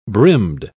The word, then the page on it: brimmed: British English